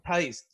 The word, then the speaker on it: Theis: Your boy Daniel Theis, is it Theis? Is that how he pronounces it? – No, Daniel Theis. – Theis, I knew that.